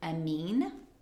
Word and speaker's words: amine: So there's also this other thing called an amine. Mm-hmm. I could be saying that wrong. – No, you're saying that right.